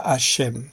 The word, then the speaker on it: Hashem: se pronuncia Hashem. Es decir, Adonai es en la sinagoga, pero en cualquier lugar que no sea un lugar de culto, se dice Hashem cuando se habla de Dios.